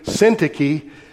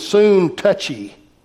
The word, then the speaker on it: Syntyche: And you notice I pronounced her name Syntyche, but it's been suggested, I've heard that's the way it's pronounced, that's the reason I pronounce it that way, but it's been suggested maybe the proper way to pronounce that is soon touchy, because something had gotten in between these ladies, and we know that that can lead to no good.